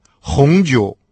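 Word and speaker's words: хунджу (红酒): А вот французы, например, вообще не представляют себе обед без бутылки красного вина – хунджу. Хун – прилагательное «красный», произносится со вторым тоном, а джоу – третий тон – это вино.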